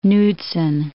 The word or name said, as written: Knudsen